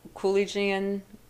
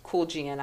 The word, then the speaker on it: Kuljian: The last name is spelled K-U-L-J-I-A-N. Although sometimes I see it misspelt, which is why I have the difficulty pronouncing it from time to time, but it's Kulgian, I believe.